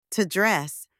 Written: to dress

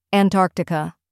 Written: Antarctica

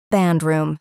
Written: band room

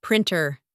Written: printer

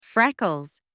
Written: freckles

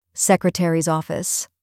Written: secretary’s office

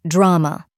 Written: drama

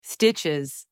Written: stitches

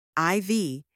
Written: IV

